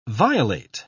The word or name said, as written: violate